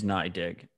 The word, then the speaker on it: NYDIG: Like, I didn't want to be the one to say it, but also, it turns out the pronunciation is NYDIG. So I'll own that NYDIG. Being wrong on that one. – And you have me saying it wrong. – What? Yeah, it's my. I mean, because I heard one of their higher-ups called it NYDIG.